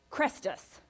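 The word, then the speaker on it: Chrestus: And this is because they were causing disturbances at the instigation of a man named Crestus, which is actually spelled differently, but is the same way that you would pronounce what we would pronounce as Christus, which is Greek for Christ.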